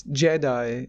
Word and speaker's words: Giedi: Like, it's called G-I-E-D-I Prime. Is it pronounced Jedi? – It's Jedi, yeah.